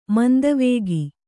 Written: ಮಂದವೇಗ

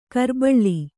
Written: ಕುರಿಬಳ್ಳಿ

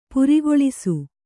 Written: ಪರಕಲಿಸು

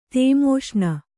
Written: ತೇಮೋಷ್ಣ

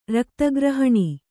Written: ರಕ್ತಗ್ರಹಣಿ

